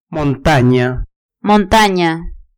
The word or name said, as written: montania